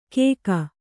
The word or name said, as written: ಕೇಕ